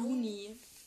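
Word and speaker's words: Loony: Looney.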